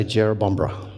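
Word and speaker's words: Jerrabomberra: We built a house down in Jerobombra, which is Jerobombra is just outside of Canberra. Everyone say Jerobombra. Jerobombra. It's Aboriginal. It's an Aboriginal word, yeah. It's Aboriginal for place, hard to pronounce.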